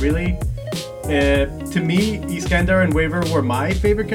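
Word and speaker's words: Iskandar: Well, first off, the pronunciation is Iskander.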